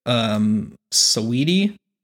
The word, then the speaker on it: Saweetie: Arlo Parks, Olivia Rodrigo, Saweetie. That's spelled weird, though. It's S-A-W-E-E-T-I-E, so Sweetie, but Saw-Edie.